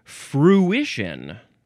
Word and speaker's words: fruition: Fruition. Fruition. So it's got fruit at the beginning, but that is not at all how you pronounce this word. F-R-U-I-T-I-O-N.